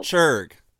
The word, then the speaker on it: Chirk: C-H-I-R-K. No apostrophe. – C-H-I-R-K. And how are you pronouncing it? – The way you were saying it, that sounds like what you just spelled. – Oh, Chirg. – Yeah.